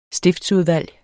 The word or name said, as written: stiftsudvalg